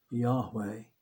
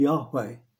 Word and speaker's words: Yahweh: And we don't really know how it was pronounced. It may have been pronounced as Yahweh.